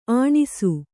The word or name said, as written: ಅಣಸು